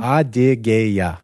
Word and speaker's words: Adygeya: And then go west of Karachay-Cherkessia, the last republic in the North Caucasus on the western end is Adygeya. – Say that again. – Adygeya. – Adygeya. – Yes. – That's how you say it.